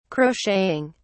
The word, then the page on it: crocheting: British English